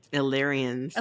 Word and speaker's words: Illyrians: The Aurelians. I'm saying that right, right? – Illyrians. – Illyrians, my God.